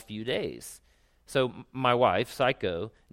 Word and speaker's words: Saiko: But he begins by saying this: my wife Saiko's family loves animals. And by the way, it's spelled S-A-I-K-O. I realize that sounds like psycho. Anyway, but it's not. It's not spelled the same way.